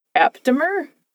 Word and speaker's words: aptamer: Aptamer? – That sounds right to me.